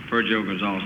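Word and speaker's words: Virgilio Gonzalez: Virgio Gonzalez, I believe, the way he pronounces it.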